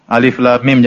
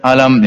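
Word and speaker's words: Alif lam mim (الم): Alif lam mim, jangan dibaca alam, ya.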